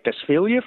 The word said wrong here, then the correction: Drosophila: fly? – Yeah, they're really Drosophila, and it is a fruit fly.